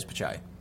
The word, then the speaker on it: Pichai: Is it Pichai? I thought it was Pichai. Did I spell it wrong? Yeah, it is Pichai.